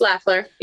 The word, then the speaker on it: Lafler: You did not. No. You said it right. It's Lafler.